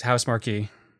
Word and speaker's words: Housemarque: No, I feel like I've heard journalists say Housemarque. Yeah, that's Housemarque. – Housemarque. Okay, maybe I've been saying it wrong.